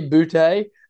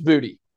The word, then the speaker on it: Boutte: Like, I think Tessitore and McElroy were just wrong the whole night, because if a player plays for LSU and it's his name is spelled that way and it's been called Boutte the whole time, I'm just like, how did it all of a sudden become Booty? Like,